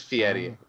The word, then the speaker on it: Fieri: And it is Fieri. That's exactly Fieri. How it's pronounced.